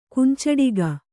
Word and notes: ಕುಂಚಿಟಿಗ: kannada

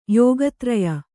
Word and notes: ಯೋಗತ್ರಯ: kannada

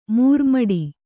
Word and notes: ಮರಮುಟ್ಟು: maramuṭṭu

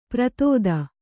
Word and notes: ಪ್ರತೋದ: pratōda

